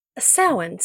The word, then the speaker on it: Samhain: How do they pronounce it? – Yeah. Samhain. Samhain. – Okay, we'll go with Samhain then.